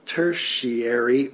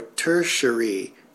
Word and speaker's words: tertiary: It's tertiary or tertiary, depending on how one wants to pronounce it. It is spelled T-E-R-T-I-A-R-Y.